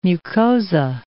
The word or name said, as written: mucosa